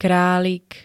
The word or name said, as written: kralik